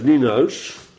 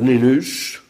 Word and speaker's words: Nijenhuis: Hoe denk je dat ze dat uitspreken, Nijenhuis? Dan spreken ze uit als Nienhuis of Ninus.